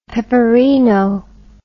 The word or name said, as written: peperino